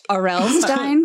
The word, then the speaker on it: R.L. Stine: It is pronounced R.L. Stine.